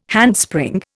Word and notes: handspring: Indian

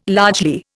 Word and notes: largely: Indian

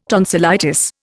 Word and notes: tonsillitis: Indian

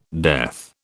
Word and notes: death: British English

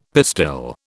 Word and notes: pistil: British English

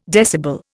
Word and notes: decibel: Indian

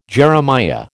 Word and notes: Jeremiah: American English

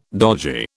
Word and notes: dodgy: British English

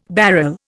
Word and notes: barrel: Indian

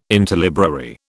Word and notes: interlibrary: British English

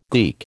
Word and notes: geek: American English

